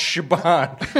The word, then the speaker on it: Siobhan: And she was like, it's pronounced Shibon.